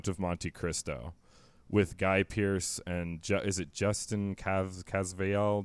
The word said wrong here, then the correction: Caviezel: Jesus, he played Jesus in The Passion of the Christ. His last name is impronounceable. Okay, there you go again. You're doing great. You're already correcting me. Yes, Jim Caviezel.